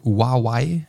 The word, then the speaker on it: Huawei: Und bei der ähm, das ist eine Huawei. Es wird tatsächlich so ausgesprochen. Huawei.